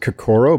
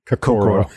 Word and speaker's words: Kokoro: Yeah, we pronounce it Kokoro, but I think your pronunciation is technically more accurate. It's just we started calling it Kokoro. – Kokoro. The American Kokoro. Pronunciation, which is totally legitimate.